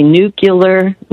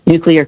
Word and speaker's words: nuclear: And then the third one that people mispronounce all the time is when they say nuclear, not correct. So... If it's cloudy today, and maybe, but tomorrow it's going to be a new clear day. Just say nuclear, and you will pronounce nuclear correctly.